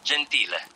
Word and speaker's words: Gentile: I mean, so we got Giovanni and, but forgive me if I mispronounce, Gentile. – Is Gentile, yeah, which actually means gentle. Just put an I in the middle.